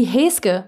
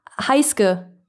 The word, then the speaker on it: Heisuke: Sowas wie Heske, was dann Heisuke geschrieben wird, ne? Wie Sasuke halt auch. Sagst du, ja, der heißt Heske. Und dann kommt Heiske.